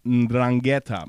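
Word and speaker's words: 'Ndrangheta: It has an N first. – Drangheta. – Ndrangheta Mafia.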